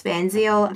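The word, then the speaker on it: Van Zeil: It's probably my favorite social media platform, and it really is just my name, at Jess Van Zeel, Z-E-I-L, because apparently that's not the way that we normally want to spell it.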